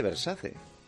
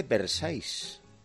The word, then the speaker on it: Versace: Me dice, no se dice Versace. Cuando dije yo, se dice Versailles. – Claro. – Y le digo, ¿no será Versace? No, Versailles.